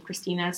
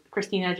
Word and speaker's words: Christina: So you can find me personally on Instagram, my name, Christina Sanguera. So Christina, just C-H-R-I-S-T-I-N-A, Sanguera. Let me spell that one. Is S-A-N-G-H-E-R-A.